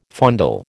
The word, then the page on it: fondle: American English